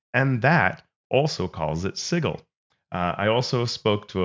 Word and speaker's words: Sigil: And it explains that to mispronounce it is to give it a J sound, and it's spelled S I J I L to make that point. So that would say that it is Sigil.